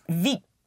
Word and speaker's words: vite: How would you pronounce V-I-T? – V. – Once more? – V. – V. How would you pronounce V-I-T-E? – Vite. – Vite. V-I-T-E. Vite means fast.